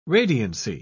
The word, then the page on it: radiancy: British English